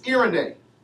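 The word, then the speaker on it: irene (εἰρήνη): Now, the word peace here in the Greek is irene. Irene, if you want to spell it, it's E-I-R-E-N-E. Irene.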